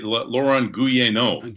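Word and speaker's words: Laurent Guyénot: Is that how you pronounce that name? Gounod? – Yeah. Laurent Gounod. – Gounod, okay.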